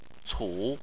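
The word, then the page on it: cu: tone 3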